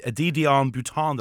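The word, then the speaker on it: De Dion-Bouton: Now, I'm going to butcher the pronunciation of this, and maybe you can help me on the way, but a Didion Bouton. Did I get that right? – Or roughly? Sounds about right.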